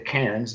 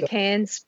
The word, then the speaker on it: Cairns: Uh, one, there's a, a, site south of uh, south of Cairns, which is a very, or people will pronounce it Cairns because that's how the, the folks from the U.S. at least, because that's not. We say Cairns. – yeah, it's Cairns.